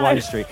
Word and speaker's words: Wind Street: To be fair, it's spelt W-I-N-D, but Wind Street sounds rubbish, so they call it Wine Street.